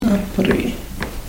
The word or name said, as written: apriih